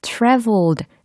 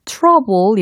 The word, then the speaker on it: traveled: 여행하기 전에 라는 부분에 traveled 라고 발음이 되었죠. 혹시 4월의 영화 trouble 이라고 발음을 하고 계시진 않는지 점검하실 필요가 있겠죠.